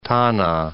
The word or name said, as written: Tana